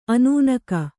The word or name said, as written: ಅನ್ನೆಗ